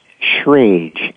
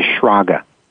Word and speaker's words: Schrage: Well, my family name was Schrage, S C H R A G E. Um, it could also be pronounced as Schrager. And that's okay.